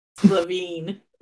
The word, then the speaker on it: Levine: This will be one of the rare times I will correct you on a pronunciation only because it's a French name I can't pronounce. Levine. I'm like, I can pronounce that one.